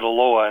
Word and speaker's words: Aloha: It's counterintuitive. It's spelled Aloha, but we pronounce it Aloha, and it's a long story.